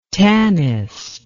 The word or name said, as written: tanist